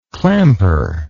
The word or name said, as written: clamper